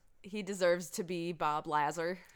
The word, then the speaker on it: Lazar: So it's not Bob Lazar, it's Bob Lazar.